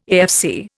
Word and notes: AFC: Indian

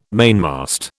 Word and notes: mainmast: British English

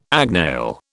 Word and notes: agnail: British English

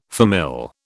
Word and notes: famille: British English

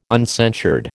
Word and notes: uncensured: American English

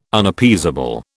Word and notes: Unappeasable: British English

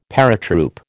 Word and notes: paratroop: American English

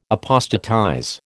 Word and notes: apostatize: American English

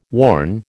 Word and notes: worn: American English

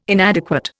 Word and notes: inadequate: Indian

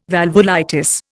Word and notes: valvulitis: Indian

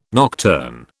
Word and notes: nocturn: British English

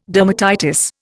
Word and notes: dermatitis: Indian

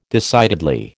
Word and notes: decidedly: American English

